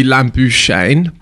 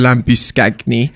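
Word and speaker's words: Labuschagne: So I looked it up and I thought it would be Lambu Shane, but apparently he's South African. So I went with the Lambu Skagni, which is what Google said if I wanted to do the authentic South African pronunciation of it.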